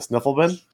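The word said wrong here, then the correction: svirfneblin: Snurfneblin. – Snurfneblin.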